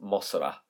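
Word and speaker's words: Mosra (モスラ): So Mothra in Japan is pronounced Mosra. It's Mosra. What does Mosra sound like? It sounds like Mothra.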